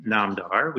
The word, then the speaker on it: Namdar: And then in the medical world, I also have my own website, uh, which is my last name, Namdar, which is spelled N A M D A R M D dot com.